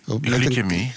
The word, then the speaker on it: glycemie: Glycemie. – Glycemie. – Spreek je het niet meer? Oh, ik dacht dat het hier met een C uitspraken. – Ja, maar je zegt nee, K.